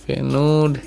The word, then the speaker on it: Feyenoord: Feyenoord, yeah, that's how you pronounce it, in their Europa League opener on Thursday.